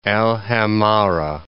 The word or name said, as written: Al 'Amarah